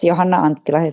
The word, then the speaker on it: Johanna Anttila: Well, it's Johanna Anttila. It's the same as in Finland, basically.